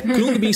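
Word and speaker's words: Khruangbin: É, Krungbin se, se escreve K-H-R-U-A. Tem um A no meio, N-G-B-I-N, mas teoricamente esse A você não pronuncia.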